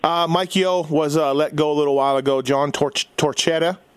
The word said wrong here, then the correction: Torchetti: Did I say that right? Torchetti? – Torchetti, yeah.